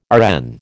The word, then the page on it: RN: American English